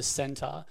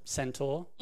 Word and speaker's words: centaur: That I, I, reckon at some point because um, there's a there's a character in the show uh, Chiron who's a centaur and um, or centaur. Mm-hmm. Both both pronunciations are fine.